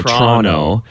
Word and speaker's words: Toronto: So you basically don't pronounce the second T at all. Toronto.